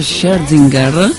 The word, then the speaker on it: Scherzinger: Connubio tra lingua italiana e lingua straniera era Ramazzotti insieme a Nicole Scherzinger, è così che si pronuncia, fino all'estasi, il nuovo singolo per questi due, che sicuramente saranno un tormento per le prossime settimane, l'ascolteremo tantissimo.